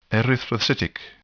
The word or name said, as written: erythrocytic